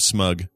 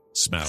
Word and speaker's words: Smaug: Speaking of the desolation of smug or smog. Mm-hmm. Smaug. – Smug. Smaug. – How do you pronounce it? – Well, it's supposed to be pronounced smaug.